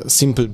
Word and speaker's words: Simple: Wiesz co, stworzyliśmy, jak dobrze wiecie, coś, co nazywamy Simple Rent, e, Simple bez E, e, bo to takie proste i, i, jak się mówi Simple, to się tego E nie wymawia, więc zrobiliśmy Simple bez E.